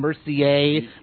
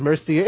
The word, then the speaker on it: Mercier: And mispronouncing Jason Mercier when it's Mercier.